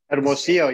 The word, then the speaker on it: Hermosillo: I mean, we saw Ortega today, and, you know, one guy that's really piqued my interest is, and correct me if I'm incorrect on this pronunciation, but Michael Hermosillo. – Hermosillo, yeah.